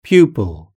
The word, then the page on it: pupil: British English